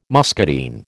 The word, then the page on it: muscadine: American English